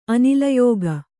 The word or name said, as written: ಅನಿಲಯೋಗ